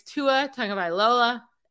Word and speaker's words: Tua Tagovailoa: My second fade of the week is Tua Tagovailoa at the Bills. – That's the best you've ever said, Tua Tagovailoa.